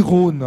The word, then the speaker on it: Rosne: Et cette rivière s'appelle le Petit Rhône. Alors pas le Rhône comme à Lyon, hein. Ça s'écrit R-O-S-N-E, mais ça se prononce de la même façon, en quelque sorte le Petit Rhône, dont une partie est encore couverte.